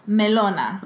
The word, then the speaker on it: Melona: Actually, I think it should be pronounced Melona.